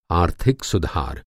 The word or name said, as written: सुधार